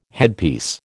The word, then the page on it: headpiece: American English